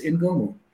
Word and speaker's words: Ingomu: You know, or how do I pronounce it correctly? And it is Ingomu.